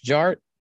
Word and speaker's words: DJ Art: And my name is Jared, aka DJ Art, with two T's for a double dose of the tink tink. The D is silent, so it's just Jart.